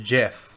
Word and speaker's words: GIF: And he cleared it up and he said it's pronounced GIF, like the peanut butter.